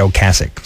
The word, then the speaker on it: Ocasek: novelty act, nothing wrong with that, but produced by Rick Ocasek, he pronounces it Ocasek, so it irks me to say Ocasek because I know it's wrong, even though that's how everybody pronounces it.